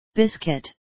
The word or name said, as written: biscuit